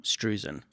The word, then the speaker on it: Struzan: Struzan.